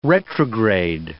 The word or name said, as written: retrograde